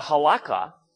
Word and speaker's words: Halakha: It's H-A-L-A. K-H-A. H-A-L-A-K-H-A.